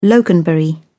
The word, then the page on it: loganberry: British English